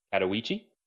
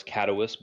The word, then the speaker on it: Katowice: We participated in IEM qualifiers for Katowice. Jenny helped me out with that pronunciation. I thought it was Katowice, but, you know, I'm just a Southern public school educated kid.